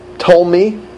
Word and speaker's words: Ptolemy: Lycia appoints three of ancient Greek's top generals, Ptolemy, silent P, Nicanor, and Gorgias.